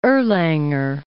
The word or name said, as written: Erlanger